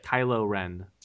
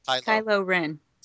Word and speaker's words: Kylo Ren: You said Kylo Ren, right? – Kylo Ren. – Kylo Ren. – Spell it. Because I can't remember off the top of my head. – K-Y-L-O space R-E-N. – Okay.